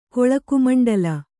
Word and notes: ಕೊಳಕುಮಂಡಲ: koḷaku maṇḍala

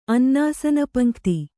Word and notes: ಅನ್ನಾಸನಪಂಕ್ತಿ: kannada

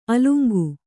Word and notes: ಅಲಂಗ: alaŋga

